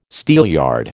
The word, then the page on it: steelyard: American English